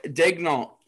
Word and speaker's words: Daigneault: It is Mark Daigneault.